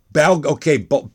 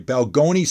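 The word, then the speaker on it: Balgonie: Is it Balogne? – It's Balgoni. – Balgoni. I'm sorry. Balgoni, Saskatchewan.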